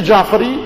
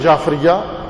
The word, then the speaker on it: جعفری: فقہ جعفری یا بقول مومنین کے فقہ جعفریہ یہ اس لیے غلط تلفظ کر رہا ہوں تاکہ مانوس یہی ہے لوگوں کے ذہنوں میں.